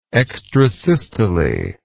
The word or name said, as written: extrasystolic